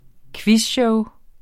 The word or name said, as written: quizshow